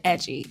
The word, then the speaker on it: Edjy: Then I tried Edgy, spelled E-D-J-Y, pronounced like edgy, and it's totally different.